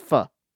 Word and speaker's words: pho: Is that how you pronounce that? – Yes. Pho is pronounced pho.